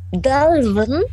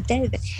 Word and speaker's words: Dalvin: Dalvin. – Ich dachte, es wird so, Delvin. Es wird genauso ausgesprochen wie geschrieben.